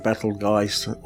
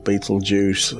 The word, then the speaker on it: Betelgeuse: So it's a bit like, depends how you want to pronounce it, Betelgeuse or Betelgeuse.